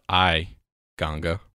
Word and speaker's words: Iganga: It's spelled I-Ganga. – Uganga? – No, Iganga.